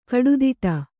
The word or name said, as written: ಕುಡುದಡಿ